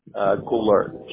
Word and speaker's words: Goulart: Goulart. G-O-U-L-A-R-T. – G-O-U-L-A-R-G? – T as in Tom.